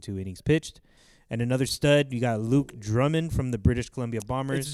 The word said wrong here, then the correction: Drummond: It's Drummond. – Drummond. I'm sorry. These Drummond. Canadians have different pronunciations.